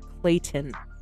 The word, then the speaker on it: Clayton: I had to try real hard to pronounce that T for you. Clayton. Sounds a lot like Layton, but we're not in Utah, so I'll try to cut the accent as much as I can.